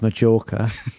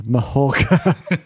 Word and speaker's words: Mallorca: Yeah, but I went to Mallorca. – Right. Well, I think you find it's pronounced Majorca. what I should have said is I think you'll find it's pronounced Mahawka.